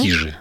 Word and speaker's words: Кижи: А я вот по поводу ударения Кижи или Кижи? – Кижи, вот они утверждают, Кижи, что да? Кижи, да. Вот возьмем себе это за норматив, ну, ага. По крайней мере, на эту программу.